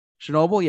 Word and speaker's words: Chernobyl: Title track: The Sick, the Dying, and the Dead, Life in Hell, Night Stalkers, featuring Ice-T, Dogs of Chernobyl. Is that, have I said that right? – Yeah. – Chernobyl, yeah.